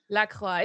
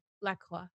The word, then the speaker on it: LaCroix: I've got my Lacroix. Is that how you say it? Oh God, you say it fancy. – Lacroix. It's like croissant, but you don't pronounce the X. So Lacroix.